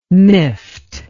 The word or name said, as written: miffed